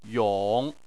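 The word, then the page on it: yong: tone 3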